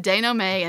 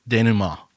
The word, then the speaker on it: denouement: And so I mispronounced denouement. I said denouement, and it is actually... – denouement.